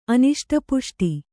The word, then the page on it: ಅನಿಷ್ಟಪುಷ್ಟಿ: Kannada